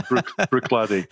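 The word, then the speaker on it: Bruichladdich: So pronounced Brooklotti looks like Bruch Ladich. And lots of people pronounce it differently, but we say Brooklotti. So shortening it down phonetically, Brook, the word for a small stream, and Laddie, the word for a small boy. So yeah, Brooklotti.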